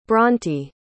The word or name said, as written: brönte